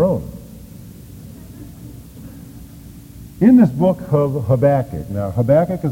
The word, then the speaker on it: Habakkuk: His name is Habakkuk, or Habakkuk, as some people pronounce it.